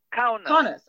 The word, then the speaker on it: Kaunas: And her grandfather, he was the mayor of the town of Kaunas. Do I say that right? – Kaunas. – Okay, for 10 years. – Kaunas. Kaunas. – Yeah, Kaunas. Yeah, that's right.